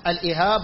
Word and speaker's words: الإهاب: طيب قال إذا ذبغ الإهاب، الإهاب قال الصنعاني على وزن كتاب، الإهاب على وزن الكتاب هكذا تنطق،